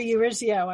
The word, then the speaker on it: Urezzio: I got a Eurizio. I get it. – Exactly. Exactly. Let's hear it for those four syllables.